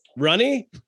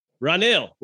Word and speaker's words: Ranil: Ranil. Ranil.